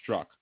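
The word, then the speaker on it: Strzok: Pronounced struck. Thank you, Elizabeth. Pete struck. Like the Z is unnecessary.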